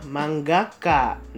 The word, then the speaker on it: mangaka: Terus kalau mangaka dibacanya manggaka.